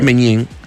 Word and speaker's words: Maignan: Maignan.